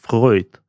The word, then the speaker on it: Фройд: они говорят, ну вообще-то правильно, Фройд.